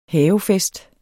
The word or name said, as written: havefest